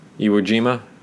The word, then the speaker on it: Iwo Jima: I made the lady friend watch uh, Letters from Iwo Jima. Iwo Jima, I think that's the way it's pronounced. Iwo Jima, not Iwo Jima.